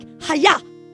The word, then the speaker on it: Hayah: It's like, Hayah. Everyone say hiya. It's like karate. Hiya.